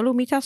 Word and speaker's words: humitas: And in this instance, is the humitas. – Humitas. – Yeah, what we should be making. Can you spell humitas so we can pronounce it correctly? – Yeah, humitas. So it's H-U-M-I-T-A-S. – Humitas, yes.